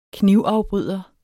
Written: knivafbryder

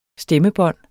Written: stemmebånd

